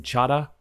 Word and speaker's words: Chadha: Uh, I'm joined by uh, uh, Yatin, uh, who is a radiologist at North York General, uh, Yatin Chadha.